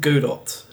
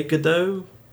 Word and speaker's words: Godot: Yeah, that's using, I believe it's pronounced, it depends who you ask, I believe it's pronounced Godot, G-O-D-O-T. Some people say it's Godot, other people say it's Godot.